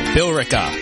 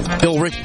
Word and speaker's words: Billerica: How do you pronounce that? Bill Rick. There's an E in there. Bill Bill Erica, maybe. Erica. Oh, that makes sense. Just leave out the E.